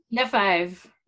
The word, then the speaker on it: Lefebvre: Lefebvre.